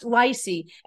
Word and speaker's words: Leisy: T-R-E-N-T, and his last name, it's pronounced Leisy, and it's spelled L-E-I-S-Y. So Google him, Trent, L-E-I-S-Y.